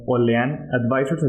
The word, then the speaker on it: Olean Advisors: Ya saben que nos pueden encontrar en nuestras redes sociales como Olean Advisors, se pronuncia como Olean Advisors, así como lo, escribieron aquí en este, en este episodio, ¿verdad?